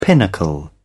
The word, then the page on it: pinnacle: British English